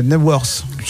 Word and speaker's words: Knebworth: on prononce pas le K hein, quand c'est comme ça, c'est Knebworth.